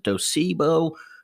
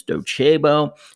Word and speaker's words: Docebo: Some people pronounce it Docebo. The correct name is Docebo and similar competitors.